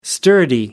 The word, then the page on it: sturdy: American English